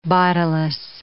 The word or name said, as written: bodiless